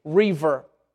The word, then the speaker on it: Roever: There's a man named Dave Reaver. His name is spelled R-O-E-V-E-R, so it kind of looks like Rover.